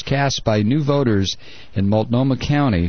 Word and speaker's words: Multnomah: How do you say that? Multnomah County? – Multnomah County. Yeah, Multnomah County.